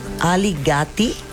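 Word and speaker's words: Ali Gatie: Sería se llama I You de Ali Gates, pero no lo sé pronunciar muy bien, pero es muy buena. – Perfecto. Ali Gatti, ¿sí es? – Es correcto.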